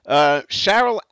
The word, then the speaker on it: Sharyl: Cheryl Atkison, and it's spelled S-H-A-R-Y-L, Cheryl Atkison.